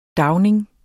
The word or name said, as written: dagning